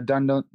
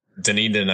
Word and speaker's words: Dunedin: He's a Dunedin. Is that how you say it? Dunedin? – Dunedin, I think.